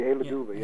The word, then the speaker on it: Hey Luduva: That you said it correctly, Hey Luduva.